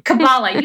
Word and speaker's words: Kabbalah: And it sounds like that's what Kabbalah represents. – Absolutely. – Yeah, I still feel like I'm saying it wrong. – Kabbalah, you got it.